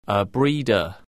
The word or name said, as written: a breeder